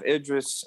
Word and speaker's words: Idris: Ildris? I cannot pronounce anybody's name. – Ildris Elba? – Yeah. Idris Elba.